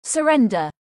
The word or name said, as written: surrender